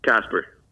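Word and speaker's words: Kasper: Casper.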